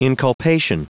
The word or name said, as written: inculpation